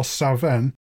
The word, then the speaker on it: Samhain: Come Samhain in Scotland, where I believe it's pronounced more Savenn, you have offerings left out Halloween time for the country's fairy cats.